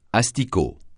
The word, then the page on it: asticot: francais